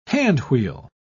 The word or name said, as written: handwheel